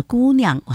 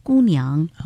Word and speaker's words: 姑娘: 那么另外呢，我就听到了某一些的词语呢，那个发音呢是有点问题的哈，比如说姑娘这个词语在演绎的过程当中呢，有时候听到了姑娘，哇，很舒服，这语音轻声词语嘛哈，但有时候呢又听到姑娘，就带了声调的哈，那要注意一下这个问题了哈。